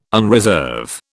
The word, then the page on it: unreserve: British English